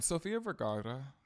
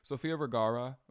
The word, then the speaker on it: Sofia Vergara: And Sofia Vergara, I can't roll my R's, and so I can't really say her name right.